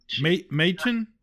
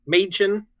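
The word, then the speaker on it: Mädchen: I've never known how to pronounce her name, but Machen. – Machen.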